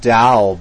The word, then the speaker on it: Tao: The great book that I found, The Tao, it's pronounced Tao, but spelled T-A-O, but many people pronounce it with a D.